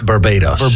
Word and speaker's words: Barbados: Barbados.